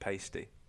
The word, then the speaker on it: pasty: That was his, which, if you listen to American podcasts about this, they pronounce it pasty.